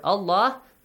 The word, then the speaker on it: Allah: If you got a kasra before the word Allah is pronounced like an a sound, with an a sound.